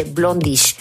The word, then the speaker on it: Blondish: Pero si quieren ver más de eso, la neta sí les recomiendo que se metan a YouTube y busquen Blondish. Así como se oye, se escribe Blondish.